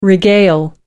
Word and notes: regale: British English

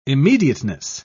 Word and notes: immediateness: British English

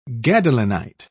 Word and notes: gadolinite: British English

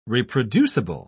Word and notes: reproducible: British English

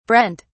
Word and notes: brent: British English

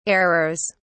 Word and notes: errors: British English

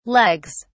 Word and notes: legs: British English

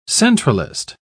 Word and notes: centralist: British English

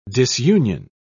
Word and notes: disunion: British English

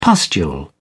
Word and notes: pustule: British English

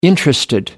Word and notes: interested: American English

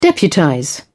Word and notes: deputize: British English

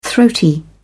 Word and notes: throaty: British English

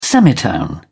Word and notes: semitone: British English

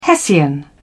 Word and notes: hessian: British English

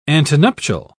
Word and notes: antenuptial: British English

